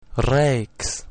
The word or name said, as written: rex